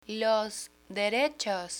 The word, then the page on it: los derechos: spanish